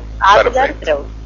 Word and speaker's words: Adler Kreutz: Adler Kreutz. – Es como digo yo, hay que partir el apellido en dos apellidos y listo.